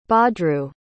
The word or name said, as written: badru